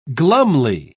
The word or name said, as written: glumly